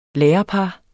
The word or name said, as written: lærerpar